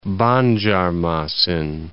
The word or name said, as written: Banjarmasin